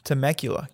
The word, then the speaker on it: Temecula: From California, Temecula, California.